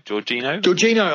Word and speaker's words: Jorginho: How do you say the Chelsea midfielder, Jorginho? – Jorginho, only 5 million, yeah.